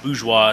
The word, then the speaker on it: Bourgeois: My name is Josh Bourgeois. It's spelled B-O-U-R-G-E-O-I-S.